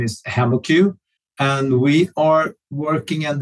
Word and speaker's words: HemoCue: Yeah, we pronounce it HemaQ. It's a mix between hemoglobin and the cuvette, which is key to the test that we provide.